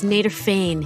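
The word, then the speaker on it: Nederveen: Naderveen Petersa. Na- no, it's Naderfane. – Naderfane. It's that Dutch like Nader- V.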